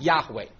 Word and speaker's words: Yahweh: Okay, and if you impose the vowels from another word, Adonai, which means the Lord, on it, you read that Yahweh.